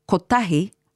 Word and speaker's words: kotahi: It's at kotahi, where sometimes a long sound is added where it isn't needed, so it sounds like kotahi. But kotahi, with no long vowel sound, is correct. Kotahi, waka kotahi.